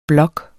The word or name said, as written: blog